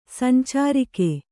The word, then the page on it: ಸಂಚಾರಕ: kannada